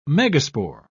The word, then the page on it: megaspore: British English